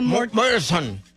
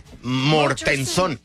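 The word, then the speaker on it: Mortenson: ¿Cómo se dice Mortensen en inglés? – Mortensen. – Ándale, Mortensen.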